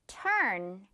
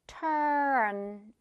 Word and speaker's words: turn: 첫 번째 단어, turn. 이거 발음할 때혀 느리게 느껴보면 턴 바로 가지 않고 turn 이렇게 올라가는 거 느낄 수 있을 거예요.